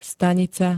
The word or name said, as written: stanica